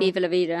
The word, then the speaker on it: Viva la vida: Diva la vida, the diva, de, I always say it wrong. – Viva la vida.